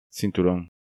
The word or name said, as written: Cinturon